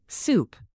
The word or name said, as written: soup